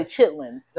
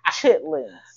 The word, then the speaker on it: chitterlings: Why are you saying it all bougie like chitterlings? – Just because I know that you would say it like that. – I would say chitlins. – No, because you do weird shit with words. So. – No, we would pronounce it chitlins. – Well, they also pronounced it chitterlings.